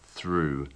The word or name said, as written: threw